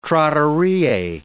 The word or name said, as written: trattorie